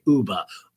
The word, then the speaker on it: Uba: Okay, Uba, rhymes with tuba, Uba, very beautiful, likes bananas.